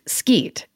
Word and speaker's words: skeet: Which is the tiny spit of land between England and Ireland, and we have some mad skeet, pronounced skeet. It means stories / gossip.